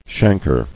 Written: Chancrous

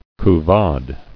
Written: Couvade

